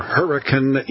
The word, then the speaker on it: Hurricane: And magic case or no case teleporter is going to take all of us to Hurricane, that's how they say it there, Hurricane, Utah. Spelled like hurricane, sounds like hurricane.